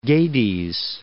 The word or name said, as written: Gades